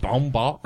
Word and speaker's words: Baumbach: Actually written by Noah Baumbach. Am I saying that right? I never say anybody's name right. – Baumbach.